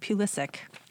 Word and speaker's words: Pulisic: I always say it wrong. Pulisic. He's an 18-year-old soccer player.